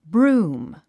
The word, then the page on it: broom: brúːm; ブルーム